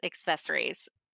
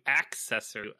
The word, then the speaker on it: accessories: There's a hard C in there that you're missing. – Oh, I don't do accessory.